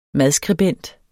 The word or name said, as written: madskribent